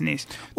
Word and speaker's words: Nice: Nice. – Yes, Nice.